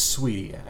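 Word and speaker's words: Saweetie: I think it looks like it's spelled Sweetie, but I believe it's Sweetie.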